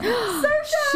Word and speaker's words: Saoirse: Saoirse Ronan, Emma Watson, or Florence Pugh? – I'm going to say Saoirse Ronan. – Saoirse!